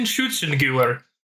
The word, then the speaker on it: Schützengewehr: The Precision Schützengewehr? – Holy shit, that sounded good! – It's Precision Schützengewehr.